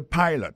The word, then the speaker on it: pilot: G-I-R-L-F-R-I-E-N-D. Girlfriend. He is a pilot. Son pilote avion lié. He is a pilot. Pilot là, c'est même mot pilote là, il dit E-A-D-L-I, il écrit P-I-L-O-T. Alors, il s'est prononcé I dans le cas. Alors, et puis, dans le mot pilot là, tout, après, accent tombé sur le premier syllabe là.